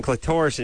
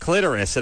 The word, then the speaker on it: clitoris: And she said, I think I was saying clitoris, and she said, you know it's pronounced clitoris.